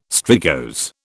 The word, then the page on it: strigose: British English